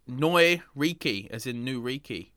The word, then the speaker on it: Neu: Neu, often mispronounced new, N-E-U exclamation mark.